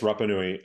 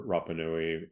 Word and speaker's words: Rapa Nui: Am I saying it correctly? – Yes, Rapa Nui. Okay. It's the modern traditional name of the island. – Okay. – And so people on the island prefer Rapa Nui over Easter Island, but which makes sense.